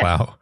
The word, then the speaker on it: Plough: the Plough, plow. Am I pronouncing that correctly? – The plow,